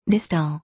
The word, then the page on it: distal: dístl; ディストゥ